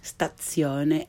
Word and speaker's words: stazione: stazione, and plaza, piazza. If you struggle to pronounce the Z in these words as ts, you're not alone.